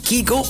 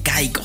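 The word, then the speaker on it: Kygo: Si es Kigo o Kaigo. Bueno, según él mismo en algunas entrevistas, dice que está ya acostumbrado a ambas pronunciaciones, pero que la pronunciación en Noruega, o que al menos sus amigos en Noruega lo llaman Kigo. Ya haciéndolo un poquito más internacional con el inglés metiendo su cuchara, pues ya la pronunciación suele usarse como Kaigo. Pero bueno, cualquiera que tú utilices está correcta, al menos para él.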